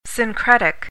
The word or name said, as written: syncretic